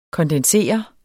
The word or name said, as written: kondensere